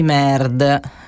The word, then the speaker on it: N.E.R.D: Sono i Nerd, scritto N-E-R-D, puntato, quindi un acronimo, però in realtà poi si legge Nerd, come i nerd, cioè quelli che poi smanettano sui computer.